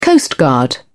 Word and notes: coastguard: British English

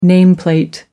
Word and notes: nameplate: American English

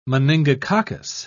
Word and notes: meningococcus: British English

